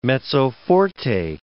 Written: mezzo forte

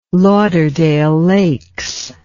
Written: Lauderdale Lakes

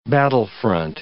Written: battlefront

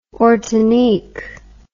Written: ortanique

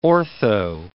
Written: ortho

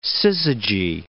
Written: syzygy

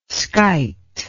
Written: skyte

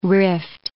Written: rift

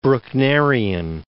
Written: Brucknerian

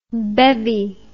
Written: bevvy

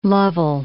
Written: Lovell

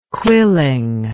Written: quilling

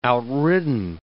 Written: outridden

